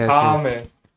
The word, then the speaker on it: Akame: Auch mit Akane. – Akame Gakil. Ah, die heißt Akame, Akame, nicht Akane. – Okay, gut. – Also mit M.